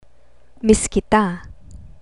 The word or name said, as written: miss kita